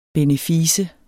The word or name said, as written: benefice